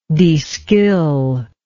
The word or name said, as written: deskill